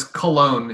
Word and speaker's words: Köln: It's Köln, but it's pronounced Köln, right? – Yeah. – But it's Cologne in English.